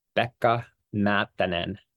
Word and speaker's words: Pekka Matinen: So let us hear the correct Finnish pronunciation of your name. – It's Pekka Matinen.